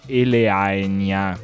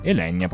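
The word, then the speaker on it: Elaenia: Elenia, una cosa difficilissima, Elenia, sì, Elenia probabilmente, un dittongo a E che si legge come nel latino, queste reminiscenze, mamma mia, quanto serve una laurea in lettere, questo è quello il risultato.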